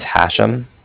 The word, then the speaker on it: Hashem: My name is Hashem. That's H-A-S-H-E-M as in mother at the end.